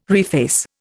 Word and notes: reface: Indian

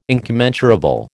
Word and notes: incommensurable: American English

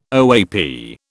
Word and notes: OAP: British English

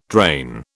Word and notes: drain: British English

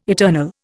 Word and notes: eternal: Indian

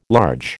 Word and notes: large: American English

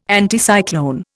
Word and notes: Anticyclone: Indian

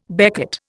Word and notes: becket: Indian

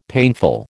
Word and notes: painful: American English

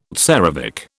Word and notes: tsarevich: British English

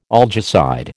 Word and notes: algicide: American English

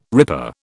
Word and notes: ripper: British English

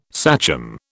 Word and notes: sachem: British English

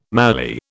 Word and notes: mallee: British English

